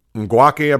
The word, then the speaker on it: Ngakoue: I can't pronounce his last name. Ngakoue, I believe that's how you pronounce his name.